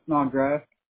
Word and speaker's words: Snodgrass: Snodgrass. S-N-O-D. – G-R-A-S-S.